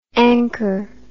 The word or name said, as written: anker